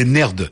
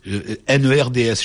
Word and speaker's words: nerd: des nerds, NERDS, je sais pas comment on prononce. – Oui, c'est encore pire que le geek, c'est vrai. – NERDS, comment on prononce? Des nerds.